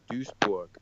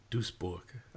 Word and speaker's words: Duisburg: So pronounce it for me. Is it Duisburg? How do you want to say the? – It's MSV Duisburg. – Duisburg. I'm not even going to do the first part, but Duisburg.